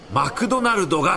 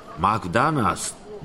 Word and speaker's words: マクドナルド: ここに日本初のマクドナルドができるのです。 – 発音が違います。マクダナースですよ。 – いやいや、それだと日本人に理解されません。ここではマクドナルドと発音します。